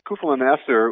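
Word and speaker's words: Keuffel & Esser: Yeah, Kuffel and Esser,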